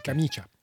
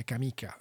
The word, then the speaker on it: camicia: Non, ça fait pas chat. Ça fait tcha. – Ah, t'as fait tcha. Okay. – Camicia. – Camicia. Okay. – Tcha, tcha, tcha. Donc, l'accent tonique est sur le dernier i. C'est sur le premier i. Camicia. Mm-hmm. Et donc, le i, on le prononce pas, le deuxième, mais il est là pour faire tch.